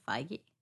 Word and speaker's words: Feige: Feige? – Kevin Feige, yeah.